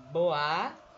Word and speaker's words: Bois: A minha arroba no Twitter é o Luciana Bois, que se pronuncia Boa. Como sempre eu falo, vou continuar ensinando. Então é arroba Luciana Bois.